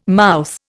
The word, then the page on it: mouth: Indian